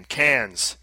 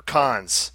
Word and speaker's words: Cannes: It's a Sonic game, Cans, or Cons. As in, like, the Cannes Film Festival.